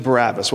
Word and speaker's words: Barabbas: The pronunciation of Barabbas' name is a little bit different than how we might say it. It's really Bar-Abbas, and Bar means son of, and Abbas, of course, means father.